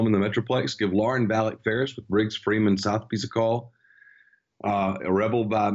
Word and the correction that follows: Sotheby's: Someone corrected me on the pronunciation of Southpies. It's Sotheby's.